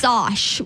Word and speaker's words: sosh: Sosh with an O. S-O-S-H. The sosh.